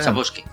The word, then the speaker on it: Sapkowski: Sapkowski.